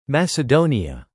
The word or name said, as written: Macedonia